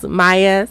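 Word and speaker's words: Maia: Welcome, my name is Maya, spelled with an M, an A, an I, and another A.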